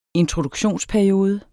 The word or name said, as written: introduktionsperiode